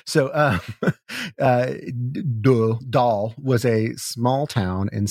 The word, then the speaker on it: Dole: Actually, I do know how that's pronounced. That's pronounced Dahl. – Dahl? – Yeah. Oh, okay. It's spelled, I looked Dole, that one up too. – D-O-L-E, Dole. But it's pronounced Dahl.